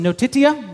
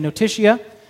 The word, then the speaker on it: notitia: The first is notitia, which I'm going to say notitia.